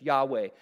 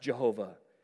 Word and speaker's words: Yahweh: How is it that you have so little regard, and he uses the personal name of God here, YHWH, Yahweh, sometimes pronounced Jehovah.